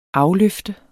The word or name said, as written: afløfte